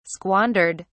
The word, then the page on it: squandered: British English